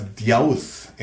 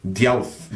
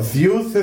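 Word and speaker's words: Deus (Ζευς): It's actually pronounced as Deus in the Greek language. Deus. – Yeah. – Zeus is a anglicized pronunciation.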